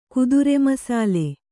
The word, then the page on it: ಕುದುರೇಮಸಾಲೆ: kudurē masāle